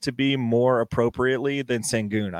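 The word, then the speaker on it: Şengün: Sengun, I believe, is the pronunciation of the last name.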